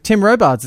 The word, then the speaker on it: Tim Robards: Tim Robards is going to be on there as well. Is that how to say the Bachelor's name? Yeah, they just announced it today.